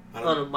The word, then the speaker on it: Hunnam: And if I had to even go deeper on it, how do you say his name? Charlie Hansen? – Charlie Hunnam? – Hunnam, my bad.